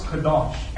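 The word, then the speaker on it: kadosh (קדוש): And in the Hebrew word, friends, that word sanctify is pronounced kadosh.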